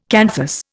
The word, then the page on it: canthus: Indian